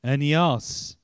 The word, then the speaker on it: Aeneas: I always think of the Hebrew pronunciation when I look him up. Aeneas, who was paralyzed and had been bedridden for 8 years.